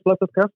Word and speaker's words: Placatka: I'm sure he pronounces his last name better than I do. Dialing once. – Hello, this is Tomas Placatka speaking.